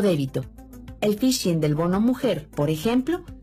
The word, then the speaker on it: phishing: La técnica utilizada en el supuesto bono mujer se llama phishing. La palabra se pronuncia igual que phishing, en inglés, es decir, pescar.